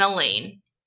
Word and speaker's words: Elaine: and Elaine, rhymes with pain.